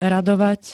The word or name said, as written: radovať